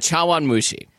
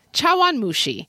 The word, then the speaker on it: chawanmushi: Oh, it's Chawanmushi. – Chawanmushi.